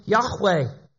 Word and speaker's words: Yahweh: We pronounce his name Yahweh, and it's actually a verb, to be.